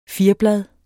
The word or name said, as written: FIRBLAD